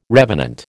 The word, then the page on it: revenant: American English